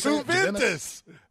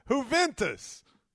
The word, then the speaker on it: Juventus: Is that it's Juventus. Juventus. – Juventus? – Hey, just forget it. 888. – I'm serious. – It's Juventus. – How do you say it? – Juventus. – Say it slower. I can't hear you. Say it. – What do you mean, say it slower? Who? Ventus. – Who? Juventus. Okay. The J is silent.